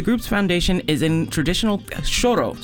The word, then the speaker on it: choro: This year's concert of samba and choro features the instrumental group Agua de Moringa, joined by vocalist Mariana Baltar.